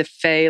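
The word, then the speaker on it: Fey: Remember, my name, first of all, is spelled Lisa Fay, like Tina Fay, EY.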